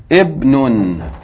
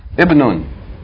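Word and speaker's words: Ibnun (ابن): It's Ibnun. Somebody want to deal with this guy? Ibnun.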